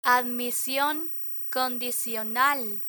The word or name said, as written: admision condicional